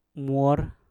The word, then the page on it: mwor: Mpur